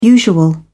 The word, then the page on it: usual: British English